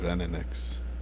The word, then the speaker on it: Venenux: Venenux.